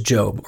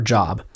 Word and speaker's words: Job: There was a man in the land of Uz whose name was Job, or Job. It's spelled J O B.